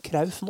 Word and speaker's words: Cruyff: Men uh, den, st uh, store greia Jeg vet ikke om det er elefanten i rommet eller hva det er, men Johan Cruyff er uttale av navnet hans. Ja. det er jo Johan Det, det er jo Krauf.